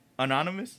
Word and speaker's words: anonymous: Whoever sent that, you guys definitely kept them how do you say it? Unanimous? Unanimous? I still can't say it. – What you think? – It's not unanimous. – So what is it then? – Anonymous? Anonymous. All right, we're learning, man.